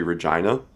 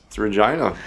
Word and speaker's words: Regina: We got corrected by a local. And we're like, Regina. You sure it's Regina? All right. – What does it rhyme with? – It doesn't, It doesn't rhyme with anything, turns out.